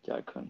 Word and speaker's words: Jharkhand: Jharkhand, did I pronounce well? – Yes, yes, you did. Jharkhand.